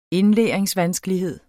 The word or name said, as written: indlæringsvanskelighed